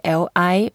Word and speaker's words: 历: And if you really want to emphasize the pronunciation, we can say L-I and the fourth tone, Li.